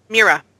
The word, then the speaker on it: Mira: And in that interview, you explained that Myra Grant? – Mira. – Mira. See, I knew I was pronouncing that wrong. – If it were Myra, it would be spelled with a Y.